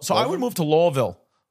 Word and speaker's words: Louisville: Looks like Louisville. – Louisville. Did you say Louisville? – That's how it's pronounced.